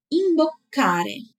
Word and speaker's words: imboccare: Ele tem dois Cs, então a pronúncia tem que ser bem caprichada. Vamos treinar só ele? Imbocare.